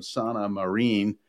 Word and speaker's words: Sanna Marin: Yeah, as a matter of fact, on Thursday, a tweet appeared from Sanna Marin, and that's the way you pronounce her name in Finnish.